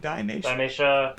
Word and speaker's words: Thymesia: Thymesia. – Mm-hmm. – I'd spell that, please. – T-H-Y... M-E-S-I-A.